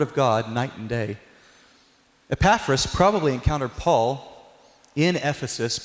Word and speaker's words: Epaphras: It's Epaphras, chapter 4.